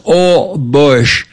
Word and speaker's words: Holbox: H-O-L-B-O-X. Of course, in Spanish, the H is silence. So it's pronounced all bush.